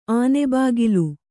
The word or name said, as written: ಆನೆಬಾಗಿಲು